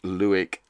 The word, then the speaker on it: Lueck: I have actually read this one, and I've even interviewed the author, who specified that her name, although it's spelt L-U-E-C-K, is pronounced Lewick as in Buick, she told me.